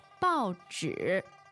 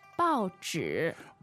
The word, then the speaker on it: 报纸: 报纸. 报纸. – 报纸.